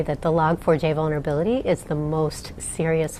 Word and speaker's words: Log4j: Sie wird geschrieben Log4J und ausgesprochen Log4J. Sie ist äußerst beliebt und deswegen auch in einer großen, unbekannten Anzahl von Programmen und Systemen verbaut worden.